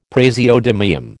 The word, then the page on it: praseodymium: American English